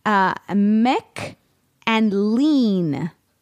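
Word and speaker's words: Maclean's: It's not pronounced the way you are pronouncing it. It is Mick and Lean, as in the word, Lane.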